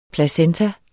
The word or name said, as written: placenta